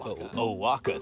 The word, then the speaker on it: Oaxaca: It happens in a city in Mexico called Oaxaca. – Thank you. It's not spelled like it sounds, so I have a little trouble with it.